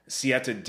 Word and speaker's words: Siete: Number one, and I'm at the website right now, I, I believe it's pronounced Siete, uh, S I E T E.